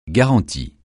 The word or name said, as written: garantie